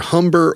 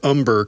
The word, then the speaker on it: Humber: But Larnell Lewis attended its either Humber or Umber College, depending on whether you pronounce the H or not,